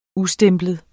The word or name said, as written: USTEMPLET